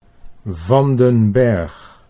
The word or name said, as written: Van den Berg